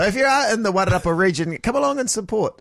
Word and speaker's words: Wairarapa: I did think of a good rap name, the Wairarapa. Wairarapa would be good for my rap name. – Wairarapa. – Yeah, Wairarapa. – Please explain it some more. – Wairarapa. – It's hard with the critics' pronunciation. Wairarapa.